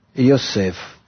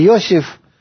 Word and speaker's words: Йосеф: Вот этот уровень, он называется Йосеф. Я произношу это на иврите – не Йосиф или как-то еще, потому что мы потом с вами будем изучать, что называется это слово Йосеф.